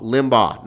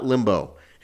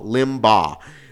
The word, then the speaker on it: Limbaugh: And by the way, I better say his name correct. Limbaugh. Limbaugh, not Limbo. Because a lot of people were correcting me last week. It's Limbaugh. Limbaugh.